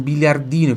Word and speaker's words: biliardino: Esse aqui é um biliardino, que ele é pequenininho, de criança. Então é um biliardino. Biliardino. Repita, biliardino. Pronuncia por do dia aqui, tá? Biliardino. Di. No. Biliardino. Biliardino. Muito bom.